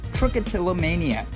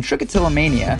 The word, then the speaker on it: trichotillomania: This week's Doctionary word is trichotillomania. Trichotillomania has 7 syllables, 16 letters, and it's got nothing to do with dinosaurs.